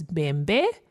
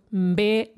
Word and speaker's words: bebe: And butterfly, and the word in Fijian is bembe, spelled B-E-B-E. Now we need to remember, especially with a letter like B, there's always like a silent M when you say words. So mbe, mbe. Can you hear the M before I say the B?